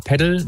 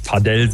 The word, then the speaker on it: Padel: Ich würde sagen, ich sage immer Paddle, man kann aber auch Paddle sagen. Ist da ein bisschen die Sache, woher man kommt. Ich glaube, im Spanischen spricht man es eher Paddle aus, aber ich glaube, solange man nicht Padel sagt, das eh dann langzieht, ist, glaube ich, ist man auf der richtigen Seite.